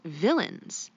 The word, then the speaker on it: villein: I believe it's pronounced villains, but it's spelled V I L L E I N. Now, this word is interesting because it also sounds like villain, like a bad guy.